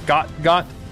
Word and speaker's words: Gout Gout: It's spelled out Gout Gout, and it sounds like he goes by like Got Got type of thing. I don't know. Got Got. We'll call him Got Got.